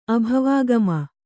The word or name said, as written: ಅಭವಾಗಮ